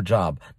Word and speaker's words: Job: It's not Job. You're looking for a job. You lost your job. No, no, no. It's Job. Job lost his job. You see? So the pronunciation is Job.